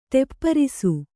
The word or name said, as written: ಟೆಪ್ಪರಿಸು